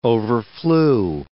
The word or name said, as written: overflew